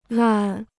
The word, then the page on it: 烂: làn